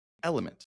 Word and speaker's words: LMNT: This is, and I didn't know how to say it for the longest time, Element, L-M-N-T, Element, electrolyte supplements.